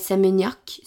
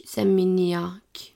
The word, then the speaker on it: Seminyak: Seminiak Nei, Seminyak Seminyak Ja klarer ikke å uttale sånne maskuline Nei, vi det tar vi siden.